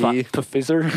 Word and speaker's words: Pfizer: Yeah, I had to look up how to pronounce that because there's a P in before. What is that? – Silent P. – Pfizer. – Love English, right? – Yeah, silent P.